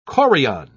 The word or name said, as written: chorion